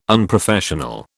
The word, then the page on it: unprofessional: British English